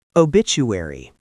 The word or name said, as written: obituary